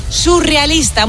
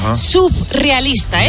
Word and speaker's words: surrealista: Y por otro lado, también decimos mal surrealista. Muchas veces la gente dice, esto me pone muy nerviosa, subrealista.